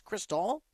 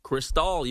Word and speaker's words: Cristall: Because our guy, Brandon Cristall, his last name is pronounced Cristall, like fancy pants. – Cristall, you got it.